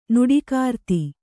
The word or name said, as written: ನುಡಿಕಾರ್ತಿ